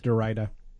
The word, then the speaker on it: Derrida: It's Derrida. – Derrida. – Yeah. – Derrida tater tots. – Rhymes with vagina.